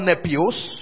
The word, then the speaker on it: nepios: So let's look at the word nepios. Nepios. Nepios is spelled N-E-P-I-O-S.